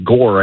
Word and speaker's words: Ngor: His name is Dang Noor, pronounced Gore, I think, is the more likely pronunciation, N-G-O-R, Dang Gore.